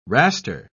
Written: raster

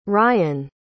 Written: ryane

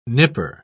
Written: nipper

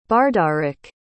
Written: bardarik

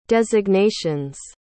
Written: designations

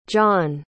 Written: jon

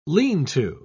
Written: lean-to

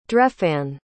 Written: drefan

